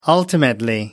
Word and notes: ultimately: American English

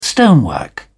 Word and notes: stonework: British English

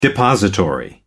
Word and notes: depository: American English

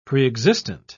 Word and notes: preexistent: British English

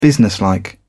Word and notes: businesslike: British English

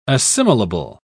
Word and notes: assimilable: British English

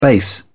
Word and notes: base: British English

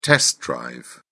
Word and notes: test drive: British English

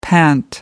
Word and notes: pant: American English

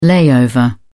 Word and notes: layover: British English

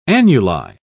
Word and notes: annuli: British English

